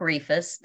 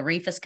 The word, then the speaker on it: Rehfuss: My name is Christina Clark Rehfuss. The Rehfuss kind of throws people a little bit. It's a long E, and just forget about that H.